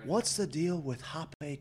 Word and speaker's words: rapé: When I began going to ayahuasca ceremonies, I noticed that in addition to the uh, ayahuasca, the shaman was offering rapé. Uh, for those of you uh, wanting to do your own research on this, it's spelt like rape with a little accent over E.